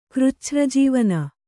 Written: ಕೃಚ್ಛ್ರಜೀವನ

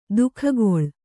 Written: ದೊಗ್ಗಳೆ

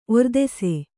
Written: ಒರ್ದೆಸೆ

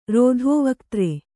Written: ರೋಧೋವಕ್ತ್ರೆ

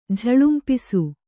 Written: ಝಳಂಪಿಸು

